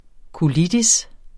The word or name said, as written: colitis